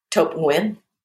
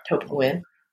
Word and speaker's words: Thuc Nguyen: Did I pronounce that right? Thuc Nguyen. – Almost. Thuc Nguyen. – Thuc Nguyen. All right. – Thuc Nguyen.